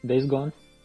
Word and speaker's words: Days Gone: De Days Gone, sei lá. Como é que fala aí? Days Gone. Isso.